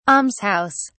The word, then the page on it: alms house: British English